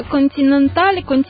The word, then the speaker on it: Континенталь: І зараз ми послухаємо композицію, якщо я вірно читаю, правильно наголоси роблю, «Талинка Континентал», правильно? – «Талинка Континенталь», «Талинка» – це закарпатський інструмент.